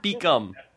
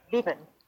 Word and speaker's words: Beekman: Is it Beekman or Beekum? – Beekman. Yeah, okay.